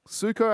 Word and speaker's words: Sueco: Suco, I think is how you say the guy's name. S-U-E-C-O.